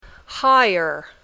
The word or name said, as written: higher